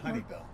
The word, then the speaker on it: Hunnibell: This is a Grand Canyon Oral History with Mark Hunibell, H-U-N-N-I-B-E-L-L. Did I pronounce that right? – Hunibell. – Hunibell, thank you.